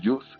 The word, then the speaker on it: Jousse: Y luego hay otro punto que es uno de los antropólogos que estudiamos, es el Marcel Jus, que es el antropólogo del gesto. No es muy conocido, se escribe Jousse, es francés, murió en el 60.